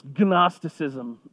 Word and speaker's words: Gnosticism: It's a lot of fun to say because there's a silent G. And how many things have a silent G? If you were to spell Gnosticism, it is G-N-O, not just N-O. So it's Gnosticism.